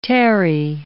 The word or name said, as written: tarry